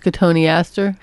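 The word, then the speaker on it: cotoneaster: And then the sixth garden is has silverbush and cotton eastern herb. It's a herb garden. – I think that's cotone aster. – Well, pronunciation isn't one of my fortes, and you are correct.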